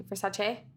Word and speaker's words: Versace: How do you say Versace? Versace? – Yeah. Perfect.